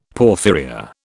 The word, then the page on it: porphyria: British English